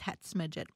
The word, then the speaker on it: Hetz Midget: 4 years ago, I had three technical arborvitaes and then five hence midget arborvitaes, if I'm pronouncing those things right. – One is techni and the other is hetz midget.